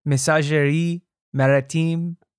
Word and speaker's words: Messageries Maritimes: Just drop like the end off every word and you've got how it's pronounced in French. – Oh, okay. Yeah. Messagerie Maritime.